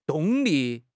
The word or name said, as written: 动力